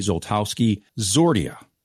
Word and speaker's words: Zoltowski-Siordia: We welcome Dr. Stephanie Zoltowski-Zordia to the podcast.